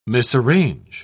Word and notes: misarrange: British English

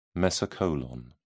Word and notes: mesocolon: British English

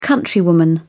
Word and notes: countrywoman: British English

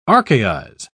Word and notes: archaize: British English

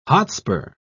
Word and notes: hotspur: British English

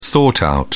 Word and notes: sort out: British English

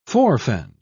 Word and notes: forefend: British English

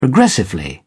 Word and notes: progressively: British English

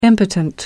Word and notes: impotent: American English

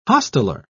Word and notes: hosteller: British English

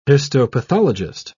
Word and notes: histopathologist: British English

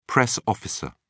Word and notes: press officer: British English

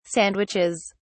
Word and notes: sandwiches: British English

